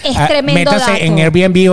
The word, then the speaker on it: Airbnb: Airbnb. Ajá, Airbnb, Que es para vacacionar y está muy de moda hoy en día.